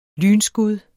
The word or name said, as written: lynskud